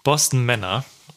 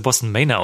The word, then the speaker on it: Boston Manor: Boston Männer. Die wir scheinbar jahrelang fälschlicherweise Boston Manor ausgesprochen haben. Ist mir ein bisschen peinlich auch. Aber sie haben sich selber zumindest so genannt.